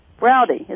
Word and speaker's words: Brody: I'm sorry, how do you pronounce that? – Rhymes with howdy. – Okay. Browdy, is that right? – Yes.